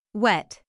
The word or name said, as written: wet